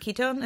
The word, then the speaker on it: ketone: And even with the ketone, is it ketone? Am I pronouncing it right? Yeah.